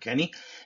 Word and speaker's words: Keunne: I think it's pronounced, spelled K-E-U-N-N-E. So I think it's Kurt Kenny.